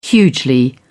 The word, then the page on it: hugely: British English